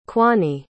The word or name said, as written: quany